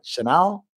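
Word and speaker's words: Chenal: Chanel? I think it's Chanel, the brand, right? – Yeah.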